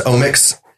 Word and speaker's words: OMX: You know, so it's just like the omics is um, kind of just how it's pronounced, and and it's spelled OMX. Yeah, it's spelled OMX. So it's omics.